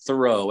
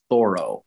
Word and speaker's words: Thoreau: Oh, by the way, it's not Thoreau. Everyone else around the country says Thoreau. If you live in Concord, the way the family name is pronounced is Thoreau.